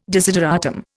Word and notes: desideratum: Indian

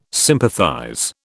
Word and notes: sympathize: British English

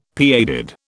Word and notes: pigheaded: British English

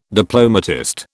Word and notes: diplomatist: British English

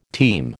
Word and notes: team: American English

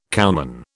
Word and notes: cowman: British English

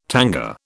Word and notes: tanga: British English

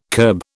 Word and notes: kerb: British English

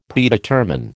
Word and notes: Predetermine: American English

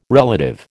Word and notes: relative: American English

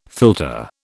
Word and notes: filter: British English

